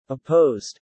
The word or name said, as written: opposed